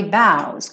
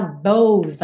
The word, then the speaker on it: bows: Seguindo, I've taken my bows. Cuidado para não falar bows aí. A, a, escrita sugere uma pronúncia fechada, mas a pronúncia é aberta.